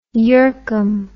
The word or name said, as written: yercum